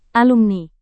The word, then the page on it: alumni: indonesian